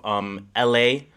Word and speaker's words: Ehlae: L.A. Came out with a record with LA. Masego. That one's called My City. Again, LA is spelled E-H-L-A-E.